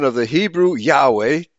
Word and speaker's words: Yahweh: The name was pronounced Yahweh. It was all in German, would be Yahweh. The W is pronounced like a V, as it was in Hebrew.